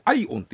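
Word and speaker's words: IOWN: これでアイオンって読むんですけどもアイ i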